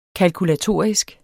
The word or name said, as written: kalkulatorisk